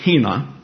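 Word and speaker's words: hina (ἵνα): It is a simple little word pronounced hina.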